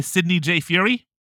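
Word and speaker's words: Sidney J. Furie: This was written and directed by Sidney J. Fury.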